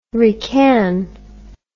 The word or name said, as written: requin